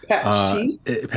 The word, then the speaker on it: pastiche: Pastiche? – Pastiche, it's a French word. P-A-S-T-I-C-H-E, I think is how it's spelled.